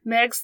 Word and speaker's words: Meigs: A Union officer named Montgomery Meigs, I think that's how you say it, I even looked up the pronunciation, it's spelled M-E-I-G-S, but I think it's Meigs.